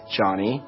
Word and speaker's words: Jony: For example, my name is Johnny, spelled J-O-N-Y. And it's normal for someone to pronounce my name like Joni, but if I were to tell you over and over again my name is Johnny and you insist on mispronouncing my name, I would feel disrespected by you.